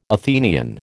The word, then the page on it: Athenian: American English